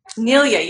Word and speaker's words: Nilia: Nilia.